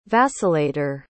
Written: vacillator

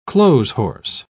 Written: clotheshorse

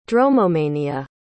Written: dromomania

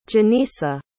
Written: Janisa

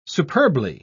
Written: superbly

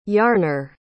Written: yarner

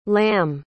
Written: lamm

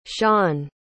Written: shon

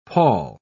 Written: pall

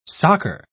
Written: soccer

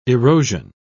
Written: erosion

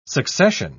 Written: succession

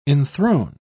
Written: enthrone

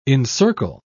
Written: encircle

